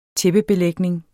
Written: tæppebelægning

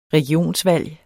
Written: regionsvalg

